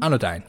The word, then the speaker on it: Anodyne: coming out on the 28th is called Anodyne. Did I say that right? – Uh, Anodyne. Anodyne. Yeah, yeah. Anodyne.